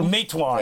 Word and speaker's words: Matewan: Matewan.